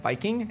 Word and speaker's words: Wiking: Which is written by an author, Mike Viking, which it's spelled, his last name is spelled with a W, but I believe it's pronounced with a V, so it's like Viking, and yes.